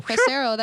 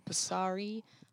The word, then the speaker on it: Pissarro: Pissarro, that never happens. Pissarro. Most people say Pissarro, Pissari. Like, that's clearly no.